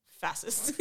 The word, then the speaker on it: fascist: She walks out with his chips and they shut the door and he's sitting there with his pie and he goes, fascist. – Yeah. – Did I say that wrong? – Fascist. – Fascist. Yeah.